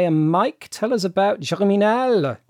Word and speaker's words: Germinal: Shall I be pretentious and keep pronouncing it in French? Germinal.